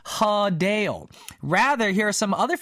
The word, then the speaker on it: Adele: Apparently, the correct way to say her name is not Adele, but we've been saying it all wrong this whole time, y'all. It's actually Hadale.